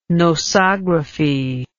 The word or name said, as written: nosography